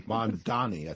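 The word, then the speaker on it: Mamdani: You mentioned the Mondavi Is it Mandavi? – Mandani, I think it is.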